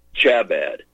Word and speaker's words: Chabad: Yeah, I like to say it phonetically. Chabad. C-H-A-B-A-D. Chabad.